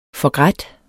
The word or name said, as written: forgrædt